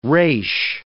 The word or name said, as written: resh